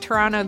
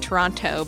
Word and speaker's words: Toronto: I'm also ashamed that you said Toronto the right way and I overpronounced it Toronto, but you, you don't say that last T.